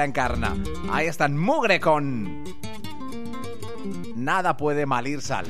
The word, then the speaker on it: Nada Puede Malir Sal: Se llaman Mugre y acaban de publicar su nuevo álbum, Nada Puede Salir Sal. No, lo he dicho mal yo. Es Nada Puede Malir Sal.